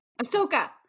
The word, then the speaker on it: Ahsoka: And I'm sorry to go back to Marvels, because I love this journey for you with Osaka. Did I say it right? – Ahsoka.